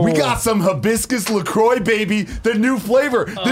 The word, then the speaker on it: LaCroix: It says, hibiscus, LaCroix sparkling water, pronounced LaCroix, was developed to give health-conscious consumers refreshment, flavor, and sparkle with an innocent twist of zero calories, zero sweeteners, and zero sodium.